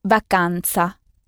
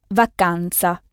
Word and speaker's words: vacanza: C'est le mot vacances, qui se dit vacanza. La vacanza. Ça s'écrit ZA à la fin. Ce n'est pas un C comme en français, mais un Z qui se prononce TZ, vacanza.